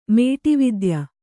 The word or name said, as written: ಮೇಟಿವಿದ್ಯೆ